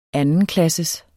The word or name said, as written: andenklasses